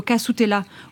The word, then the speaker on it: castella: C'est une importation qui en évoque une autre, celle du castella, ou qu'on prononce plutôt casutella au Japon, un gâteau d'origine portugaise qui ressemble beaucoup au chiffon cake introduit au Japon dans la province de Nagasaki par des marchands portugais.